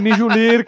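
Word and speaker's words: Mjölnir: A pronúncia certa é Mjolnir, cara.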